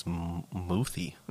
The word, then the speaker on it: muthi: The molete is a catch-all term for traditional African medicine, but is it multi, or am I saying that wrong? – I think it's muthi.